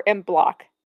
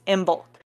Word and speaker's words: Imbolc: Wait, is M bulk or M block? Where's the L? – So it is spelled I-M-B-O-L-C, but it's pronounced M bulk.